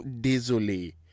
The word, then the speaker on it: désolé: When you want to say, I'm really sorry in French, yeah, you say, je suis vraiment désolé.